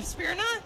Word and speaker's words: Smyrna: From, I'm sorry, I'm going to pronounce this on Smyrna, Tennessee, maybe? – Smyrna, probably? – Smyrna. – S-M-Y-R-N-A. Well, here comes the punt team again with another dropped interception.